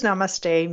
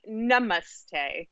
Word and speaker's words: namaste: So what does namaste mean, and did I pronounce it correctly? – Well, sort of. They should really be. So in Sanskrit, there's a difference between a short and a long ah. Uh-huh.